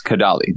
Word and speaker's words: Kadalie: And it's Kadalie.